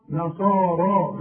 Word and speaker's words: نصارى: شوف أو نصارى، وبرضو الياء دي بيقول لك حولها لي إلى ألف، ولازم لي ألف فوق الياء، تكتب هكذا وتنطق نصارى بالألف.